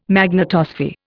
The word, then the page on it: magnetosphere: Indian